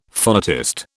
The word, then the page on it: phonetist: British English